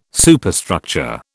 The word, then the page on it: Superstructure: British English